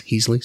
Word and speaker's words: Heasley: And when I say my name, I mean it's S. Heasley. So it looks like Sheasley.